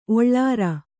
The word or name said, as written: ಒಳ್ಳೂರ್